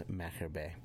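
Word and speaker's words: Machir Bay: And, you know, what, and by the way, it's pronounced Macher Bay.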